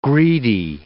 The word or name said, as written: greedy